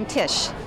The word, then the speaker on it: Tish: I'm Tish. – Can you spell that for me, please? – T-I-S-H.